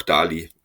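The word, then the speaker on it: Dæhli: Deshalb bin ich jetzt bei dieser Billo-Aussprache geblieben und sage einfach Mats Möller Dali, aber ich nehme auch Dali, also alles cool.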